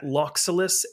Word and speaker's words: Loxxulus: You can follow me on Twitter at Loxulus, L-O-X-X-U-L-U-S. And Chesslock is spelled L-O-C-K-E at the end.